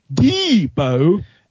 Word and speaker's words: Deebo: Also, Deebo Samuel, that's how you say his name. Deebo Samuel, South Carolina, wide receiver, San Francisco 49ers. You don't go Deebo every time he touches the ball. His name is Deebo. Not you don't shout the first syllable of his first name and then...